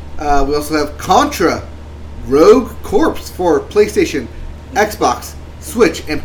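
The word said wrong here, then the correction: Corps: Rogue Core. – Corpse. – Core. – Corpse. There's a P. – Yeah, you don't pronounce it.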